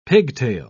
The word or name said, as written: pigtail